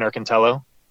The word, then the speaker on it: Erkintalo: It's Steven Erkintalo.